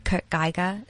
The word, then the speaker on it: Kurt Geiger: I don't know if I'm going to say this right, but Kurt Geiger, is that how we pronounce yes.